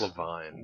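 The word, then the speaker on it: LaVine: It's pronounced Levine.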